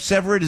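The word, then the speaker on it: Severud: Did I get the name right of Severed? Is that correct the way you pronounce it, sir? – You did get it right. That's how it's said.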